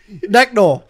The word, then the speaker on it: Neck Knoll: It rhymes with Rickroll. Necknol.